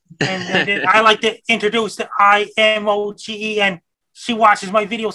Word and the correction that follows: Imogen: Imogen is how you say that.